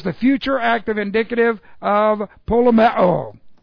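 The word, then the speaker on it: polemeo (πολεμέω): Okay, let's see, polemeo, and what we have here, this is P-O-L-E-M-E long O, polemeo.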